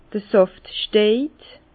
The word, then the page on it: d'r Sàft stejt: Bas-Rhin; Herrlisheim